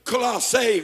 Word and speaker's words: Colossae: If you study Colossae would be the correct pronunciation.